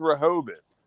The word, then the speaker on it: Rehoboth: Rehoboth Beach Crew and Rehoboth. Fat Blue Security. Rehoboth, okay. – That's Rehoboth.